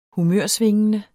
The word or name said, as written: humørsvingende